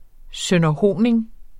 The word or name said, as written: sønderhoning